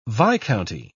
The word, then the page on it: viscounty: British English